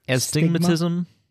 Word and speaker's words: astigmatism: Astigmatism? – Mm-hmm.